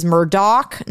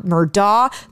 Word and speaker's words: Murdaugh: It is Murdaugh, not Murdaugh. The father's name is Alec, not Alex, it is spelled. – But it's spelled with an X. – It is spelled Alex Murdaugh. It is pronounced Alec Murdaugh. – That's a completely different name. You can't just change your name. – I don't. – The Murdaugh one I kind of get, but Alex, like with an X and you pronounce it Alec, is wild.